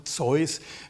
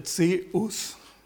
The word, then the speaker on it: Zeus: Er lebte einen Gott vor, der eigentlich, könnte man das so jetzt deutlich machen, also Zeus ist so die neugriechische Bezeichnung, altgriechisch spricht man das eigentlich so, ein bisschen anders aus, der Zeus, also das ist so die Aussprache im Altgriechischen.